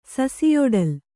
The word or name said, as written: ಸಸಿಯೊಡಲ್